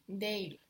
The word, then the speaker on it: değil: Değil is spelled D-E-G, and this G has a kind of upside down point above the G, which is showing us it's a soft G, pronounced actually like a Y. And then it's I-L, D-E-G, with this mark on the top, I-L.